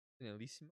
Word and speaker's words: Finalissima: You pronounce that? – Finalissima? – Yes. – I think you said it right.